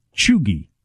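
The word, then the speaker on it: cheugy: You pronounce it Chugi. Chugi.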